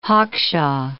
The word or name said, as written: hawkshaw